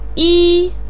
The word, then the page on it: yi: 1